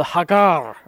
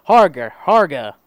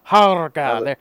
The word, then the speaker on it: Harga: The Hagar. Or the Harger. Harga. Harga. – You're going to have to spell that. – H-A-R-G-A.